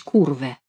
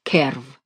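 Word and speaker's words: curve: По-русски прочесть «курве». По-английски произносится «керв».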